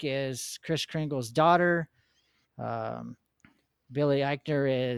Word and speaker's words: Eichner: Eichner. – Eichner, okay.